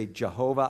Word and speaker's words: Jehovah: We say Yahweh, Some people say Jehovah.